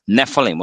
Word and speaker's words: Nephilim: In this variant spelling in number thirteen, we see a scribal gloss indicating the base word of Nephilim was only pronounced with a long E.